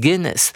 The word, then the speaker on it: Guinness: 好，我们看到这个金氏世界纪录，这个字要念成 Guinness，它是创办人的名字。